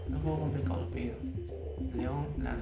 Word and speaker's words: León: Entonces, es una obra de arte escuchar a cantar a Leo Larregui. Eh, se llama Leo. ¿Cómo se transcribe? ¿Cómo se escribe? Leo. – León.